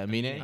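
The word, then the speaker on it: Aminé: Amine, Amine released a new mixtape. – Is that how you say his name? – Yeah, Amine. – Amine. – It is Amine.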